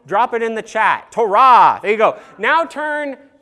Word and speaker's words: Torah: The Hebrew word for what those first 5 books are called is the word Torah or Torah. That's what it was called when I was growing up. And then Ben decided to pronounce it the Hebrew way.